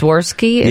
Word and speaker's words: Swarovski: Oh, Swarovski? – Yeah.